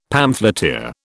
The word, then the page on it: pamphleteer: British English